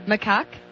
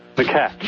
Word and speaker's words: macaque: You are saying it is pronounced M-A-H hyphen K-A-K. – That would be correct. That's the phonetic. – Macaque? – Macaque.